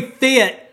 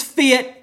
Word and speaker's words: Fit: My name's Fit. My name's going to be Fit. And look, my name's Fit. It rhymes with it.